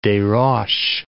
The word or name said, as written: Desroches